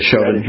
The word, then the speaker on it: Chauvin: Part of the problem is that I understand that this Chauvin, I'm pronouncing his name right, the officer. – Yes, Chauvin, Derek Chauvin, yes.